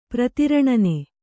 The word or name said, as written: ಪ್ರತಿರಣನೆ